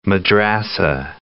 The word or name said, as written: madrassa